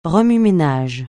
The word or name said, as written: remue-ménage